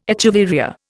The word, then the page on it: echeveria: Indian